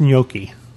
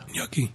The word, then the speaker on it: gnocchi: They had, likes gnocchi. And I'm sure there are a thousand other pronunciations by that. That's how I was. How do you pronounce it? – Gnocchi.